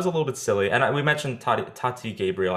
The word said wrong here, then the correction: Gabrielle: I hope I'm saying her name right. Or Gabrielle, I'm sorry.